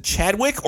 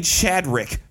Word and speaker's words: Chadwick: Is it Chadwick or I keep saying Chadrick for some odd reason. It's like apparently I got a Chadrick somewhere stuck in my head.